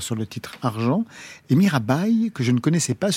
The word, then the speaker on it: Mirabai: Alors j'ai dit Mirabai, mais peut-être que c'est Mirabai. – Mirabé. – Mirabé. – On prononcer Mirabé, Qui est-elle Mirabe était une princesse euh, du XVIe siècle qui était une chanteuse en fait, une artiste, euh, une chanteuse, danseuse.